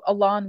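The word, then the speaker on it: Aloné: Yeah, so Alon Watts, who's the sort of central woman of that work, her name is spelled Alone and it has an accent on the E. It's pronounced Alon, but it's spelled Alone.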